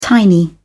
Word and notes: tiny: British English

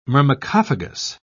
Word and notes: myrmecophagous: British English

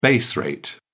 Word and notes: base rate: British English

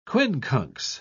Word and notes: quincunx: British English

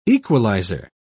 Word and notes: equaliser: British English

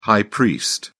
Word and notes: high priest: British English

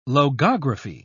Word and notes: logography: British English

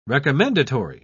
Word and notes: recommendatory: British English

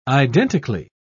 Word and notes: identically: British English

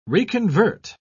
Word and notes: reconvert: British English

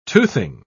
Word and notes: toothing: British English

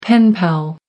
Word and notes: pen pal: American English

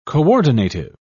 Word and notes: coordinative: British English

